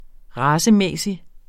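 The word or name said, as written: racemæssig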